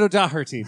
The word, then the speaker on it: O'Doherty: My bright spot is the comedian David O'Doherty, Dan. Mm. – You might have overpronounced that name. – David O'Doherty, Dan. You pronounce it right.